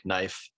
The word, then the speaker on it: knife: Because we have letters that are silent, like knife. The K is silent.